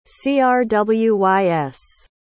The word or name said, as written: crwys